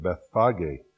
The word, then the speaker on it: Bethphage: There is no silent E in the Greek, so you pronounce the long E at the end, Bethphage.